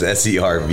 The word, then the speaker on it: SERVEHZAH: Yeah, so phonetically in English is SERVEHZAH. – Yep. Got you. – And then so our website, our Instagram, Facebook, everything's just SERVEHZAH.